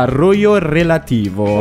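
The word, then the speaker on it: Rojo Relativo: Intanto il suo album Rosso Relativo viene tradotto in varie lingue, tra cui lo spagnolo, quindi diventa Rollo Relativo.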